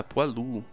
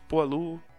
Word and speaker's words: poilu: Porque foado, patê de fígado de ganso, olha aí, gordo, pensando em comida, se escreve foi e se lê foá, então eu suponho que pó e se lê poá também. – Ah, poalu. Faz sentido, eu acho que poalu Fica até melhor de pronunciar.